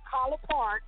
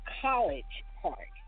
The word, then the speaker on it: College Park: Y'all gonna be in Collar Park, that's how they say it. Don't say the whole word. – Mm-hmm. – It's not College Park. It's Collar Park.